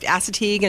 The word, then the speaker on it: Assateague: It's pronounced Assateague and Chincoteague.